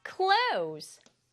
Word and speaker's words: close: close, cl, o, z 소리 나는 s에 이 소리 나지 않아요.